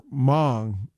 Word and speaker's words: Hmong: And it was about him as well as some of the neighbors that moved in were Hmong. And it's spelled with an H, but the H is silent.